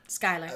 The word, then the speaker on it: Schuyler: As a Peggy Schuyler? – Skylar. – Skylar, pardon me.